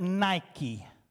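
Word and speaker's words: Nike (νίκη): Now, four different times, John is going to use the Greek word Nike. We pronounce it Nike.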